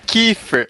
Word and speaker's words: Kiefer: Então, Kiefer, é falei certo? Falou totalmente errado. Não, tá. É, é, é Kiefer. Que, é tipo Kiefer Sutherland? Esse mesmo.